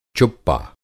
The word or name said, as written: चुप्पा